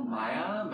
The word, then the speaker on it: Maia: So her name is spelled M-A-I-A, so... – Yeah, Maya Mitchell.